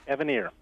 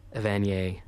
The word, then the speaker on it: Evanier: Evanier, right. Okay. I was corrected a while ago because I'm a Canadian, I was pronouncing it, I think the en français, Evanier.